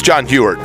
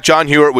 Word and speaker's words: Huarte: Sorry, it's John Hewart. I, I, I, John Hewart is the the way you pronounce it. I'm saying. – Okay, say those one more time. – Uh, Roger Staubach, John Hewitt, which is not correct, which is why I mispronounced it.